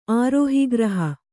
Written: ಆರೋಹಿಗ್ರಹ

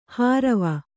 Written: ಹಾರವ

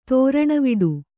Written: ದಾರುಣವಡೆ

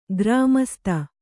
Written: ಗ್ರಾಮಸ್ಥ

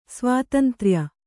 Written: ಸ್ವಾತಂತ್ರ್ಯ

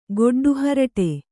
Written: ಗೊಡ್ಡುಹರಟೆ

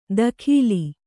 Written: ದಖೀಲಿ

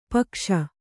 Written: ಪೀಕಿಸು

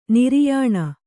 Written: ನಾರಾಯಣ

